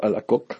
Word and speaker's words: Alacoque: Como saben, el asesor espiritual de Santa Margarita María Alacoque, o Alacoque, como se pronuncia en francés.